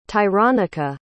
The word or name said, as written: tyronica